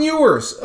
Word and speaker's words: Ewers: Oh, Quinn Ewers for Texas.